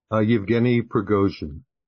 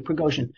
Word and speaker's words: Yevgeny Prigozhin: Yevgeny Prigozhin. – Thank you, Prigozhin.